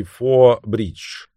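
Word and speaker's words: Forth Bridge: Английский «Фор Бридж» — мост через залив Ферт оф Форт у восточного берега Шотландии. Название читается так же, как «Четвертый мост».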